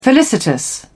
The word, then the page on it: felicitous: British English